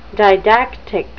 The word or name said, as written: didactic